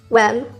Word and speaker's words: worm: Gusano, worm, o bien worm, se pronuncia con una especie de E, y tibio, que se escribe warm, se pronuncia con una especie de O.